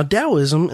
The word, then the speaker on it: Taoism: Now, Taoism, and it's spelled with a T, but pronounced with a D,